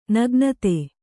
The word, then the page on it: ನಗ್ನತೆ: Kannada